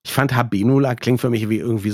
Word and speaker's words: Habenula: Habenula, ist das richtig ausgesprochen? – Ja, würde ich auch so aussprechen.